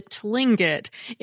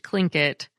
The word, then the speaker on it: Tlingit: Thanks to both Ben and Cynthia, who pointed out that the Alaskan native people are not the Tlingit. It's pronounced Tlingit.